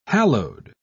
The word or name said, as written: hallowed